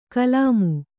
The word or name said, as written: ಕುಲಿಮೆ